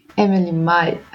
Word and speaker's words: Emelie Mai: ja, ja, wenn man es liest, weiß man ja meistens nicht, ist es Englisch oder Deutsch ausgesprochen. – Naja, das stimmt. Aber nee, nee, Mai ist schon richtig.